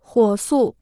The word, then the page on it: 火速: huǒ sù